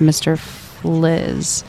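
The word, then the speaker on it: Mr. Phylzzz: It's spelled like Mr. Phil's, but it's pronounced Mr. Fliz.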